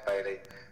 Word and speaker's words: Baily: And my new tag on Instagram is Alex Bailey, and then Alex Bailey Music on Twitter and Facebook, I think.